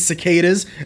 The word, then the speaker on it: cicada: cicadas. And that's right, that's how it's pronounced, not cicada.